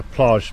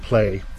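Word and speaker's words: Plaj: Well, everybody's calling it a plage, but it's pronounced play.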